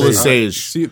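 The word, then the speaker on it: LeSieg: And he used several pen names, including Theo Lysage. – Theo LeSage. – Theo LeSage. – Yeah, French. That's the one. That's because it's a soft G.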